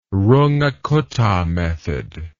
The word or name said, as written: Runge-Kutta method